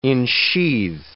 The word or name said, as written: ensheathe